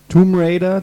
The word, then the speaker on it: Tomb Raider: Tomb Raider, das B wird nicht ausgesprochen.